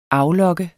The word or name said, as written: aflokke